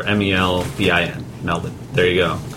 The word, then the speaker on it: Melbourne: Melbourne's? – Melbourne. No, it's pronounced Melbourne. – Melbourne. Yeah. – Melbourne. – There's an R in it, therefore it's Melbourne.